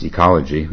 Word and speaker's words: oecology: So O-E, C-O-L-O-G-Y. He says, I guess it's pronounced ecology, even with the O.